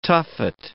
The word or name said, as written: tuffet